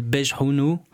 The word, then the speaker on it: Bghawni: On va passer euh, à la prochaine musique euh, qui euh, a été écrite par El Grande Toto qui s'appelle Bejhounou, qui s'écrit B-G-H-A-W-N-I. Que tu as très bien dit.